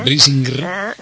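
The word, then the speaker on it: Brisingr: Del segon no se n'ha fet, però se suposa que això continuarà, Un títol difícil de pronunciar, no? Brising. – Sí, Brisinger.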